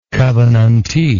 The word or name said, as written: covenantee